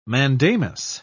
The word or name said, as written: mandamus